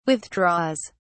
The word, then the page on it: withdraws: British English